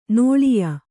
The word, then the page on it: ನಿಲಯ: nilaya